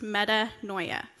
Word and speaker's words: metanoia: The Greek word for repentance is metanoia. And so you can see that up on the screen. And it's pronounced metanoia.